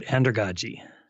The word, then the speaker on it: andragogy: Now, I don't know how to pronounce this word, andragogy. – That's right, andragogy.